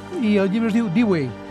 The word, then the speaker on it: Dewey: Jo m'atreviria a suggerir la pronunciació Dewey.